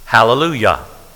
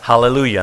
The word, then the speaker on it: hallelujah: And if you spell that out, it ends with J-A-H. It doesn't end with Y-A-H, though we pronounce it that way. We don't say hallelujah. It's hallelujah. Thus, we could say pronounce that ja or ya, but it is praise to Jehovah.